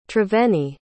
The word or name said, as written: triveni